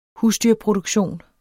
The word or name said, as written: husdyrproduktion